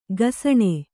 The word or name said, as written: ಘಸಣೆ